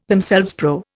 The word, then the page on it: themselves: Indian